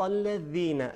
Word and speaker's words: الذین: صراط الذین زال ہے کہ زبان کی نوک سے تلفظ ہوتا ہے.